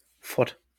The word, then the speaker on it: Fott: Ford, ich gehe davon aus. F-O-T-T. – Fort. Fort. Also es bedeutet übersetzt, es ist äh, Kölsch und bedeutet, was fort ist, ist fort.